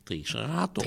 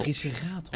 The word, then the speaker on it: Triceratops: Dus jij bedoelt dat ik de waarheid in het midden moet laten liggen of je Triceratops. – Triceratops. – En dat komt omdat de klemtoon in het Nederlands op de tweede lettergreep van achteren valt in het algemeen.